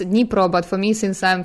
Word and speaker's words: Dnipro: Currently, the name is shortened to Dnipro because of our political situation in Ukraine right now.